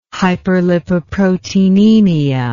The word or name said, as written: hyperlipoproteinemia